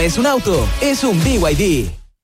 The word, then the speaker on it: BYD: ¿VIB? ¿No es B&B? – Nada que ver. Se escribe B&B, pero se dice BYD.